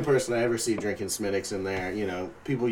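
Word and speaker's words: Smithwick's: But I think mostly it's just the fact that I like that I know how to pronounce Smittics, even though it's spelled Smithwicks.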